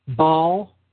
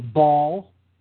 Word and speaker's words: Baal: Phonetically, the name Baal can be rendered Baal or Ball, Baal, Bell, Bill, or Bull.